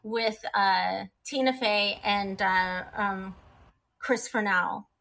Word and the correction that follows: Parnell: Chris Parnell.